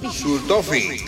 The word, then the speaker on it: Sultofi: La Sultopi, insulina Sultopi. – Sultofi. – Sultofi, eso, Sultofi. Usted que es prácticamente alemán, pronuncia mejor que yo.